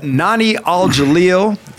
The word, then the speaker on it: Nohnie Al-Jalil: This morning, we have Nohnie Al-Jalil.